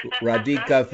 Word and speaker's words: Radhika: The H is silent. – Okay. – Radhika Vaz plays Fats, and Nadia Mazur plays Shugs.